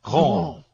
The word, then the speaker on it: Ghent: G-H-E-N-T, but they pronounce it Gaunt.